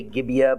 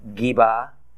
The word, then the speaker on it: Gibeah: Typically, we say Gibeah, but more correctly, it's pronounced Geba, in his tribal territory of Benjamin.